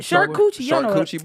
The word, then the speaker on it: charcuterie: I'm finna have the dopest fucking charcuterie board. – What is it called? – Charcuterie board. Because I'm finna put. – Charcuterie. – She says charcuterie. – Charcuterie board.